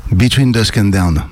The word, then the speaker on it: Between Dusk and Dawn: Ça se prononce comme ça? – Between Dusk and Dawn. – Voilà, c'est mieux. – C'était presque. – C'est mieux.